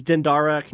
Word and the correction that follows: Dandara: Can you spell it? – Dandara, Dandara, which there's an actual Brazilian connection there as well, just in case you're interested.